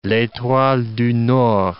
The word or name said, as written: l'etoile du nord